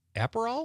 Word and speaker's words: Aperol: Liz from Sun Prairie says she's going to have tequila and soda with a lime or a splash of Aperol. Am I saying that right? – Yep, you got it.